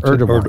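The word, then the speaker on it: Erdoğan: President Trump said the only reason he was going, well, basically was for Erdogan, or how do you pronounce Erdogan.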